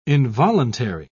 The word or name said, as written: involuntary